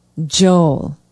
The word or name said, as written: joel